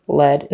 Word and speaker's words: lead: Lead, spelled the same way, L-E-A-D, means to be in charge, be in front, show people where to go. The little boy wanted to lead, E-E, with a long E sound, even though it's spelled the same way as lead.